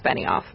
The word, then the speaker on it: Benioff: I don't know how to pronounce this guy's name. B-E-N-I-O-F-F? – It's Benioff. – Benioff.